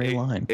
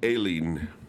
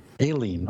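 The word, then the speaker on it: Aline: We have Robert Crumb and, how did we say this, Aileen or A-Line? – Aileen. – Aileen.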